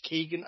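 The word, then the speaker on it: Keoghan: But yeah, let's, you know, people are watching this because Barry, and I think it's meant to be pronounced Keegan, I think.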